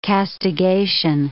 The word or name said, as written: castigation